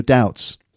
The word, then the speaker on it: doubt: Anyway, the thing here is that the pronunciation of doubt, it does have a B in it, D-O-U-B-T, but the B is silent. So it's doubt, not doubt. Okay, doubt. Silent B.